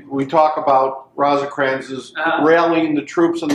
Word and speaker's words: Rosecrans: Does Rosecrans even...